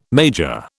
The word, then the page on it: major: British English